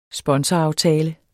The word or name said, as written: sponsoraftale